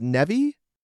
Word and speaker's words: Nevi: And things like, why Nev, is it pronounced Nevi? – Yeah, it's Nevi.